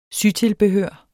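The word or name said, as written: sytilbehør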